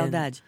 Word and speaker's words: saudade: Oui, la saudade.